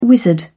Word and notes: wizard: British English

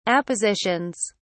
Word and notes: appositions: British English

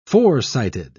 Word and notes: forecited: British English

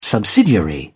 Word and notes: subsidiary: British English

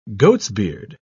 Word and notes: goatsbeard: British English

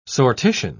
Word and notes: sortition: British English

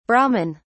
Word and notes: brahmin: British English

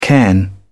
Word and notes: cairn: British English

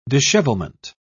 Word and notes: dishevelment: British English

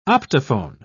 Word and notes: optophone: British English

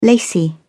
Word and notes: lacy: British English

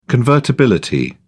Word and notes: convertibility: British English

